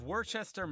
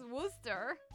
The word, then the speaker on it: Worcester: Just south of Worcester, Mass., near the Connecticut border, lies Lake Webster. Also known as Lake Chargagagamanchuagagachugagchuabunagugamuag. And here I was worried about my pronunciation of Worcester. – Which I think is Worcester.